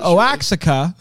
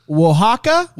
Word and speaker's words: Oaxaca: Oaxaca. – Oh my gosh. – Here we go. – We don't know. Oaxaca. – Oaxaca. – Oaxaca? – Oaxaca. – Okay. – Oaxaca. – But then, so Oaxaca is the city.